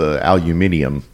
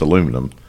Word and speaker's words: aluminum: It's kind of like the way the British mispronounce aluminium. – Aluminium. – Aluminium. – Aluminium. – Yeah. When we know it's aluminum.